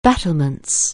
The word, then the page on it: battlements: anglais